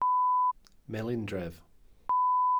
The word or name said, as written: melindref